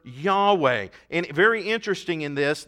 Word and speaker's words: Yahweh: And to pronounce that name, the Jews used the vowels from the name Adonai, which means Lord, capital L, then lowercase O-R-D, and turned YHWH into Yahweh, which is translated Lord in all caps.